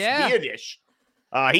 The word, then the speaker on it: Biadasz: And speaking of guys we covered for the draft, Tyler Biadasz. I remember his name pronounced Biadasz.